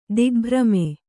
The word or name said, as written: ದಿಗ್ಭ್ರಮೆ